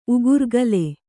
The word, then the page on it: ಉಗುರ್ಗಲೆ: Kannada